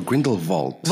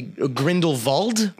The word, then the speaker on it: Grindelwald: Is it Grindelwald or Grindelwald? – Well, okay, so if you want to pronounce it properly, it would most likely be Grindelwald. – Yeah. – But all the Americans in the film say Grindelwald, yeah.